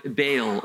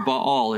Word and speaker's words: Baal: Beelzebub was one of the names of the Philistine god Baal or Baal.